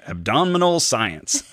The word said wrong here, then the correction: abominable: And he'd be like, no, Dad, abominable.